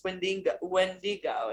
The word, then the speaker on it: Wendigo: It's Wendigo, and you're saying Wendigo. – Like Wendigo. Wendigo. Okay. Proper.